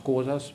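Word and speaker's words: Xhosa: The Xhosas were, it's also pronounced Xhosas, but we all pronounce, we have to have a kind of click, but I'm struggling with that all my life.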